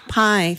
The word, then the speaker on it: Pai: And the Democrats actually did request that the FCC chairman, I'm not sure the correct pronunciation. – Hard to say. – Arjit Pai? Pai? – Yeah. – Pai. Thank you, Clark.